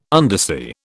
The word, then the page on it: undersea: British English